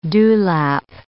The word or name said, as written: dewlap